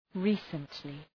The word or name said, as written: recently